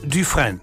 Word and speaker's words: Dufresne: Und der schreibt sich folgendermaßen: D-U-F-R-E-S-N-E. Etwa ausgesprochen Dufresne. Dufresne. Ja. Etwa so, ja? – Ja.